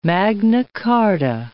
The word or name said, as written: Magna Carta